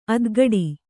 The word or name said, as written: ಆತುಕುಡಿ